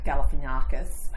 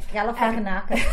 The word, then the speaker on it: Galifianakis: And it's got Jason Schwartzman in it and Zach Galifianakis, who... – I have never heard that name pronounced. – Oh, really? – Yeah, Galifianakis.